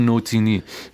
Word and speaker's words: Nutini: نوتینی تلفظش هم اینجوری که پی ای او ال او ان یو تی I N